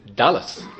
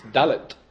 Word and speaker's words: Dalet: And Dalet also, it's actually strictly the pronunciation is Dalas. It's Dalet.